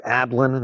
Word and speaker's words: Ablan: My name is Matthew Ablan. That's spelled with an A-B-L-A-N.